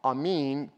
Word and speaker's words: amen: The Hebrew word amen, amin, pronounced in Hebrew, literally means this is true.